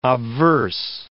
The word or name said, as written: obverse